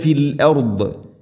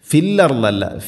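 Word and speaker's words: ഫിൽ അർദ് (في الأرض): വയുഫ്സിദൂന ഫിൽ അർദ്, ഫിൽ അർദല്ല ഫിൽ അർദ്, ഫിൽ അർദ് ഫിൽ അർദ്. വഖഫ് ചെയ്യുമ്പോൾ അർദ് ഇങ്ങനെ ദാദിനെ പ്രത്യേകം ശ്രദ്ധിച്ച് ഉച്ചരിക്കണം.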